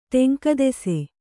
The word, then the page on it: ಟೆಂಕದೆಸೆ: kannada